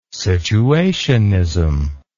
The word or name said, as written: situationism